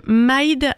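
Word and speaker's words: Myd: Et l'artiste, c'est Maïd. Alors, c'est français, ça s'écrit M-Y-D, c'est sorti en 2017 et ça nous vient de Lille.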